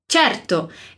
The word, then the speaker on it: certo: Então, diz, certo. Esse certo se escreve com C e E no início, que sempre vai ter esse som che, che.